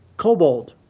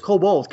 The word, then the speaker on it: Kobold: How do we spell that, by the way? – Which one? – Kobol. – Kobold. – Is it kobold? – Kobold. KOBOLD. Like yeah.